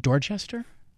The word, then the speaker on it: Dorchester: Is it Dorchester? – You can use every letter in that word.